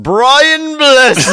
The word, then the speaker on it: Brian Blessed: I do believe it's pronounced Brian Blessed.